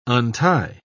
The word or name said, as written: untie